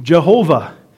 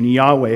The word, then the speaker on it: Yahweh: order to get the name Jehovah, which is assuredly not correct. I don't think that it's wrong necessarily, but it's definitely not correct. And so we've decided to use the pronunciation Yahweh. It's as good as any that we're going to have at this point.